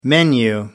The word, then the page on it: menu: American English